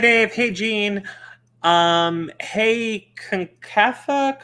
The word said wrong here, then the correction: CONCACAF: Concofo, Concobama Cup. – Conca, I would CONCACAF. – CONCACAF.